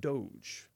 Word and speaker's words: Doge: Doge. D-O-G-E.